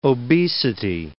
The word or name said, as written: obesity